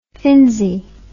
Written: finzi

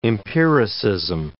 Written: empiricism

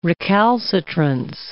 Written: recalcitrance

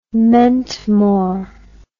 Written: mentmore

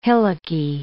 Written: hillocky

